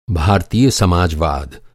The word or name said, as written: समाजवाद